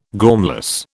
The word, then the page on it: gormless: British English